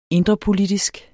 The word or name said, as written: indrepolitisk